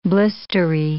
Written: blistery

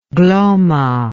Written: Glomma

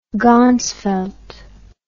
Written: ganzfeld

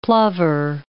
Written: plover

